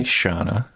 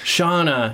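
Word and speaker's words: Seána: This is the feature-length debut of director Lee Cronin, who also wrote the movie with Stephen Shields, and it stars Sienna Kerslake. Sorry if I butchered that. – It's probably Shauna. – Shauna, yes, you're probably right.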